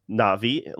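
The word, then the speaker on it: Na'vi: As our resident Navi export, or Navi, like as they kept on pronouncing it, like the hard syllable break throughout the entire trailer, like a dozen times at least through from the start to finish.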